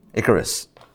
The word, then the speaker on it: Icarus: But I wrote in the email about the story of Icarus. Am I pronouncing that correct? Yeah? Icarus.